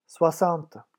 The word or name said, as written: soixante